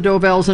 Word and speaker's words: Dovells: The Del Vos. – Number six. – Not saying it right. – That's all right. You know it's the Dovells, and I'll give it to you for that.